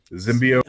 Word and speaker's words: Zimbio: Z-I-M-B-I-O. Zimbio. Zimbio. – Z-I-M-B-I-O.